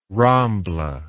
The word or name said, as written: rambla